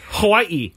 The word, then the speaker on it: Hawaii: Yes, because that is how it's said, Hawaii.